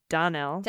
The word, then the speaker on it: Danelle: Danelle, pronounced with emphasis on the da. Donnell. Donnell. – Ah, Donnell.